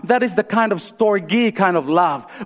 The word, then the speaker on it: storge: How do you say that? Storge, there you go. Storge.